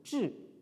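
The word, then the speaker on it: zhi (知): Mais ici, je pense qu'il est à prendre comme substantif ou comme nom, et donc il se prononce donc au quatrième ton, zhi.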